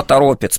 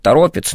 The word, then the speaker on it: Торопец: Некоторые ставят ударение на второе Торопец. – О, Торопец, потому что река, которая протекает через город, называется Торопа. И, наверное, правильнее говорить Торопец, но я замечал, что люди привыкли по-разному, что кто-то так, да, называет, кто-то так.